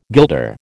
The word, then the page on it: guilder: American English